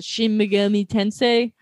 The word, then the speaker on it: Shin Megami Tensei: But in, I can never pronounce it, Shin Megami Tensei, how you say it? – No, yeah, that's correct.